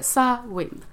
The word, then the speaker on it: Samhain: Uh, irländare är väldigt känsliga kring, kring, kring uttalet, kring sina traditioner. Så uh, Sa Win, sa det uttalas det.